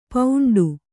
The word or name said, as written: ಪೌಂಡು